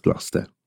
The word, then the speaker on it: Gloucester: or Gloucester, if you, depending on where you're from, if you were to look at it written down, it's spelt Gloucester, but it's pronounced Gloucester.